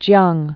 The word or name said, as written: Yuan Jiang